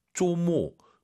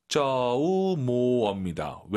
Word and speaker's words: 周末: 조모. 조모. – 아하. 应该是 조모. – 조우모어입니다. 왜? 오유는 어예요. 조우모어. 어로 끝내야 됩니다.